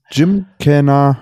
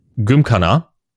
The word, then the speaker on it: Gymkhana: Dann müsste es Gymkhana heißen. So. – Ja, die englische Aussprache, ne? Wir Deutschen sagen natürlich Gymkhana.